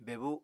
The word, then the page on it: bebu: Apatani